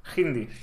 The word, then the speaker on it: Hindi: O sea, la H se pronuncia con J, ¿eh? Ahora lo estoy viendo. Hindi.